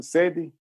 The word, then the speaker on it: Cedi: So, the currency is the Cedi. Is that how you pronounce it? Cedi? Ghanaian Cedi? – Cedi, yeah. – Cedi.